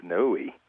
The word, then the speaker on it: Niue: It's the island is Niue, and it's one of the largest coral islands in the world, and it's in the South Pacific in Polynesia.